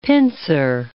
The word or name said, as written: pincer